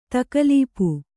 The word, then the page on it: ತಗಲೂಪಿ: tagalūpi